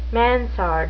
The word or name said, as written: mansard